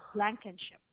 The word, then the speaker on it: Blankenship: So that's Blankenship.